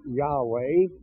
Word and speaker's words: Yahweh: Now, we started out with a recognition that God reveals himself ultimately by the name Yahweh. Y-A-H-W-E-H is a way to transcribe it into English, probably with the accent on the last syllable.